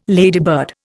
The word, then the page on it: ladybird: Indian